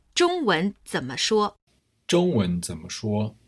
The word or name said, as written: zhongwen zenme shuo